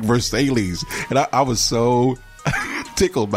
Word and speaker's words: Versailles: I said Versailles, Missouri. – Versailles. – It's actually Versailles.